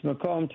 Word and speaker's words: Macomb: Oh, it's Macomb Township, but they always Macomb.